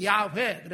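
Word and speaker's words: 야훼 (יהוה): 정말 정확한 발음은 야훼.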